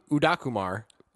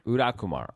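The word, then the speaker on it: Udayakumar: but it's Ravi Udakumar. There's a Y in there that Mike Sexton doesn't pronounce, so I'm not going to pronounce it either. – Udakumar.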